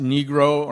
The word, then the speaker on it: Nigro: because Laura's born in the Bronx to a family named N-I-G-R-O, which was actually pronounced Negro or Nigro.